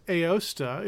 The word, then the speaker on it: Aosta: He was born in, I believe it's pronounced Aosta, in what is now Italy.